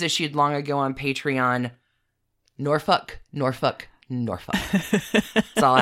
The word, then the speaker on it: Norfolk: I now know it is Norfolk, not Norfolk.